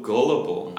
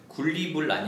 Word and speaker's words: gullible: 네, he's so gullible. 아예 다른 형용사 있어요, 영어로. 이렇게 모든 것을 사기 같은 거, 거짓말 같은 거 다 믿어버려요. 그러면은 he's so gullible 이라고 하면 되고 스펠링은 G-U-L-L-I-B-L-E입니다. – 굴리불 아니고요. Gullible.